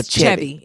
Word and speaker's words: Chevy: Now, am I saying this right? Is it Chevy or Chevy? – It's Chevy.